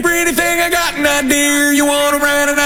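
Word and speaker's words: Idear: It's I-D-E-A-R. So you could shorten it to Idea if you want to, but it's pronounced Idea, I'm sure.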